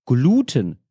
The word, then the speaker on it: Gluten: Ich habe irgendwo auf Social Media mal gehört, dass eine Person Gluten gesagt hat und vorher dachte ich immer, das wird auf dem E betont und nicht auf dem U. Seitdem bin ich jedes Mal verwirrt. Immer wenn ich das Leuten erzähle, lachen die über die Betonung auf U. In der heutigen Folge, so wie ich das verstanden habe, sagt er auch, dass die Betonung auf U, also Gluten, richtig sei.